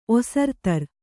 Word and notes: ಒಸರ್ತರ್: Kannada

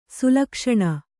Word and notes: ಶ್ಲಕ್ಷ್ಣ: ślakṣṇa

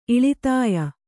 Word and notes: ಇಳಿತಾಯ: iḷitāya

